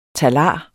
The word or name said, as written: talar